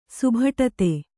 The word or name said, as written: ಸುಭಟತೆ